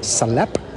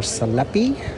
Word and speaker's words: salepi: So as you wander through the streets of Athens, you see little stands that are set up, and they're selling salep, or salepi, as he pronounced it when I tried it.